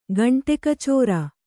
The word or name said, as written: ಗಂಟೆಕಚೋರ